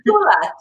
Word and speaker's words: Tula: So, of course, I go by my nickname, Tula. It's very easy to reserve a spot with it. Tula, T-U-L-A.